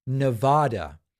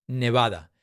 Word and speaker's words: Nevada: And then Howie says, love your stuff, but it's pronounced Nevada, not Nevada.